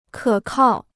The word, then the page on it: 可靠: kě kào